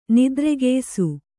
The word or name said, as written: ನಿದ್ರೆಗೆಯ್ಸು